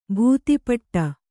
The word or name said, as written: ಭೂತಿಪಟ್ಟ